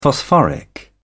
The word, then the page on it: phosphoric: British English